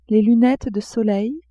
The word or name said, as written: lunettes de soleil